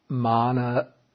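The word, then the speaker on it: māna: I don't know if I can say it correctly. Is it tanha, mana, and ditti? – Right. Tanha, mana, and ditti. That is craving, conceit, and wrong views or speculative views.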